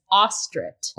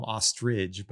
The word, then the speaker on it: Ostrit: Okay, I wrote it out phonetically, guys. It is Ostrit. – Ostrit, yeah. Lord Ostrit, who, is this other character who's introduced, who works for the king. So we find out it's Ostrit. I want to call him Ostrich,